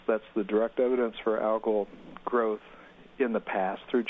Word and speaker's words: algal: So there was an increase in algal, and is it algal or algal? – Algal is probably the more common pronunciation.